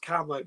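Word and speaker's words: Kamo: Oh, we are, and that's pronounced Carmo, by the way, after the All Black.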